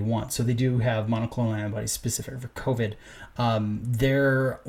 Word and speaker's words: monoclonal: So the monoclonal antibodies, am I saying that right? – Monoclonal antibodies, yes.